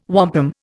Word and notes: wampum: Indian

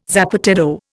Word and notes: zapateado: Indian

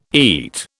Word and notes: eat: British English

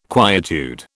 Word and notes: quietude: British English

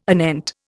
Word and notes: anent: Indian

